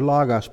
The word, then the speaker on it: logos: And we saw that the Greek word translated Word is the word logos, L-O-G-O-S, pronounced logos.